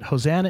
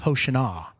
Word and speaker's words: Hosanna: And I didn't mention Hosanna yet, but Hosanna is actually pronounced Hoshana. Hoshana is an Aramaic word that means save us now.